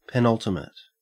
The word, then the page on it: penultimate: Australian English